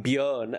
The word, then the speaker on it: Björn: And if you want to make it abroad, it's not going to cut it because no one can pronounce Agneta and no one knows what to do with those two dots in Björn, as it's pronounced, if you want to pronounce it correctly.